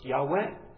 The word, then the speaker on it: Yahweh: was a shortened form of the covenant name Jehovah, or better pronounced Yahweh, the name Lord in all capital letters in your Bible.